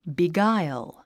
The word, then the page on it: beguile: bigáil; ビガァイゥ